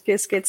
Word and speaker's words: Qiskit: And I think about that in the sense of OpenShift, but also, you know, I know Qiskit, if that's how you say it, Qiskit. – Yeah. It's, everybody has their own pronunciation, but yeah. The creators of Qiskit call it Qiskit, so I call it Qiskit.